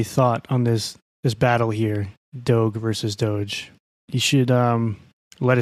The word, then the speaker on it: Doge: No, I do GIF with a hard G, but Doge, like dog. Doge? Not like dojo.